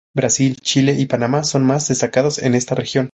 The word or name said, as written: destacados